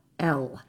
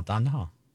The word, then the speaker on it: Domhnall: Okay, so it's spelled Domhnall. – Yeah. – And it's pronounced Domhnall. – Domhnall. – Oh, Domhnall. – Gleeson. – Domhnall. Domhnall. Okay.